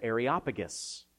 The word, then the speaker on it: Areopagus: And we get to this one passage in Acts chapter 17, and the Apostle Paul is appealing to the logic of the Areopagus.